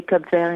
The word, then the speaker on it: Cape Verde: How do you say Cape Verde in English? – Cape Verde, yeah.